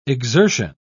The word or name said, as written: exertion